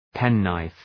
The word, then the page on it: penknife: ‘pen,naıf